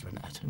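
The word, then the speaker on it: Reinette: It's Renette. Well, I think it's Renette. We all chose to pronounce it Renette.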